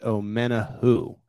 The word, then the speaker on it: Omenihu: It's pronounced Omenihu. So just to correct myself there as we move forward, it's spelled Omenihu. So it could go either way, but I gotta lock that in my brain before I go on 610 Sports Radio with just friends here at the Arrowhead Pride podcast network.